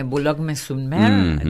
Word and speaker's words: Boulogne-sur-Mer: Boulogne-sur-Mer, digo bien, algo así. – Esa pronunciación, muy bien.